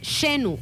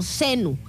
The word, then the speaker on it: Xenu: que es conocido en esta teoría como Xenu o Xenu, Se escribe con X, pues X-E-N-U.